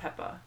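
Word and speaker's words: Pepa: Salt and Pepper. – Yeah. – Or, I said that wrong. I'm sorry. I said Pepper, not Peppa.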